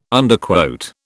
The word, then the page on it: Underquote: British English